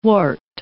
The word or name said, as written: wart